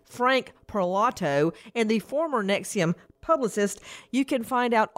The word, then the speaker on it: NXIVM: NXIVM, pronounced Nexium.